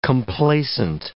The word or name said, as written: complacent